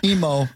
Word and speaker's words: Emo: Emu, how do you say it? Emu? Emu? – Emo. But Emo.